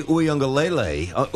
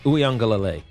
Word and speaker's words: Uiagalelei: Speaking of benching quarterbacks, former five-star recruit DJ Uiagalelei. – You said it right. Uiagalelei.